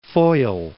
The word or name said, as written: Foyle